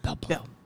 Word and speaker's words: bourbon: Babon. Babon. Babon. You gotta fill your cheeks like a little chipmunk.